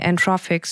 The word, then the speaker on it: Anthropic: Ich habe gesehen, ihr arbeitet zusammen mit, ich hoffe, ich spreche die alle richtig aus, OpenAI, Anthropic, Suno, Perplexity, Midjourney, Cognition, 11 Labs, LangChain, Pinecone. Habe ich was falsch ausgesprochen? – Die sind alle perfekt tatsächlich.